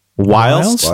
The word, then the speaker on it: whilst: Is that how you properly say it? Whilst? – Whilst. – Yeah. We're kind of educational. – It's spelled whilst. Whilst. – W-H-I-L-S-T. – Whilst. – Yeah, whilst. – That's whilst. – Why is the I whilst. Long in that? – It's like while.